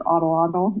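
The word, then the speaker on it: Atlatl: It's like Adelaide. It's like, no, it's Ottolottle, you know.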